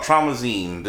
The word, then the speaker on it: Traumazine: Traumazine. That's how you say it? Like, that's what the album's called. It's called Traumazine. – No, Traumazine? Oh, yeah, it is, huh? – Yeah, that's what it's called, Traumazine.